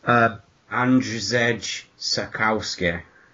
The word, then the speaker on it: Andrzej Sapkowski: Andrzej Sapkowski. – Yeah, go with that. Andrzej Sapkowski.